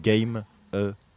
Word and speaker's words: Gamma: Ah ouais, c'est parce que c'est un petit jeu de mots, parce que tout le monde écrit ça Gamma comme un rayon Gamma, mais parce qu'en fait je suis pas censé le prononcer, je suis censé faire l'américain. Quand on a monté ça, on faisait de la musique pour les jeux vidéo et du coup exactement, et du coup c'est Game a Prod.